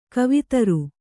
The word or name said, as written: ಕವಿತರು